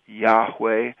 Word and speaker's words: Yahweh: That the four-letter name of God, pronounced, Yahweh,